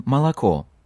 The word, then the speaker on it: молоко: We already discussed briefly how to pronounce unstressed O's in Russian words. They turn into A's, like in молоко, milk, молоко, which has three O's, but only the very last one is read as O, the others sound like A.